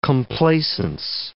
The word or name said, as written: complaisance